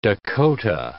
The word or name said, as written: Dakota